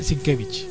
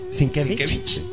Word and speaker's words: Sienkiewicz: En realidad se dice Sienkiewicz. – Sienkiewicz.